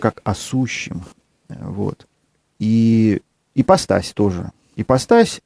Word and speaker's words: ипостась: Ипостась – это греческое слово, которое мы так и произносим, ипостась. Ипостасис, как оно по-гречески, в принципе, и звучит.